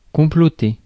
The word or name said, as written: plot